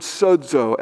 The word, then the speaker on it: sozo: It's the Greek word sozo, S-O-Z-O is how we'd spell it in English.